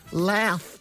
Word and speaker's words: Louth: Do you think they'll come back, Ray, and they'll have forgotten how to pronounce certain Irish places? Like they'll say County Meath, County Louth. – Louth. Dermot, can you stop with the didgeridoo?